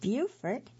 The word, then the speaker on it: Beaufort: And for those of you who may not be local to the Carolinas, Beaufort is spelled B-E-A-U-F-O-R-T. And there's a town that's spelled the same way in North Carolina. But in South Carolina, that word is pronounced Beaufort. And in North Carolina, it's pronounced Beaufort, which I'm guessing many of you know, maybe not all.